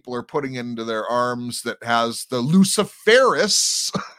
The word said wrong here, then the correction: Luciferase: in it. No, but we're just crazy people. – Lucifer Ace. – Luciferase, that's right. – There was an emphasis on the ace there.